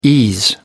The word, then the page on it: ease: American English